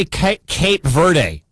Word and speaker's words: Cape Verde: Also, how do I say Cape Verde? How do I say that country? – Either way, you said it right.